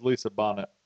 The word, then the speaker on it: Bonet: Her name is Lisa Bonet. That's how you pronounce it.